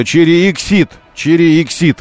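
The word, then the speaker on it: Exeed: Поэтому пришлось называть автомобиль Cherry Exit в одно слово с ударением на серединку, и тогда Cherry Exit, вот так это произносится.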